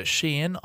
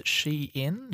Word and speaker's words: Shein: We should talk about the Shein IPO. I believe that's how you pronounce it. Shein, yep.